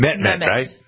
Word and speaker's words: Mehmet: Mehmet.